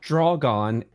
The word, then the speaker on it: Drogon: It's Drogon, and it's spelled D-R-O-G-O-N. And Drogon.